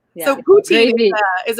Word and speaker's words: poutine: It's poutine. – How do you spell it? Put with P? – P-O-U? Mm-hmm. T-I-N-E.